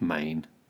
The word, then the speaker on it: mane: What's Maine? – It's an alternative pronunciation of the word man.